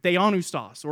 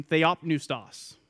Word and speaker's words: theopneustos: Theanoustos, or if you prefer the other pronunciation, theopneustos, whichever one you prefer.